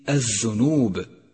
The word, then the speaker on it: الذنوب: ثالثا، سقطت لام كلمة الذنوب من اللفظ لأنها لام شمسية، وهذه اللام تكتب ولا تلفظ، ولذلك لونت بالرمادي في مصحف التجويد إشارة إلى عدم لفظها.